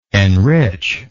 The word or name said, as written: enrichingly